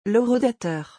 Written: l'horodateur

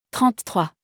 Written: 33

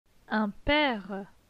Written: Un pere

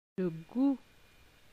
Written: Le gout

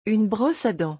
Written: Une brosse a dent

